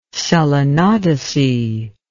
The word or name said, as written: selenodesy